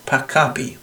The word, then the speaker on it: Pakapi: That's B-L-O-Q-E-D-E-L-S-U-R from Argentina, and that's from the Papaki Records, which is Papaki Records. No, I can't say it right. Pacapi, that's right. Pacapi Records, P-A-K-A-P-I.